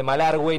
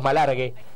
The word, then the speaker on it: Malargüe: ¿Cómo me gusta, Richard, que pronuncies la diéresis de Malargüe y no le digas Malargue? – Y lo pronuncia como corresponde. – Se pronuncia como corresponde.